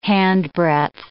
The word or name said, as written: handbreadth